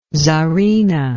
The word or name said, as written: tsarina